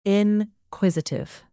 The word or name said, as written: inquisitive